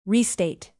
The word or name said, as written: restate